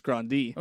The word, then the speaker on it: Grande: No, most people say grande, Ariana Grande, but it's supposed to be pronounced grande.